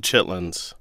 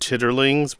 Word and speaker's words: chitlins: Ode to Chitlins. Note it's spelled C-H-I-T-L-I-N-S, not chitterlings, but chitlins, and the poem talks about that.